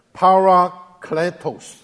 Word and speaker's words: parakletos: 希腊文念作是 parakletos. – In Hebrew, it's parakletos.